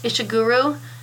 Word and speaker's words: Ishiguro: Ishiguro.